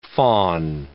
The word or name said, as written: faun